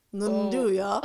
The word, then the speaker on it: nduja: We got that with burrata, prosciutto, nduja. – Nduja.